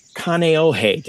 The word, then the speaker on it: Kaneohe: Kaneohe.